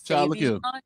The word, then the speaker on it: Sauvignon: I was in the studio with my producer and I realized I've been pronouncing Cabernet Sauvignon wrong my entire life. It's Sauvignon. It's Sauvignon.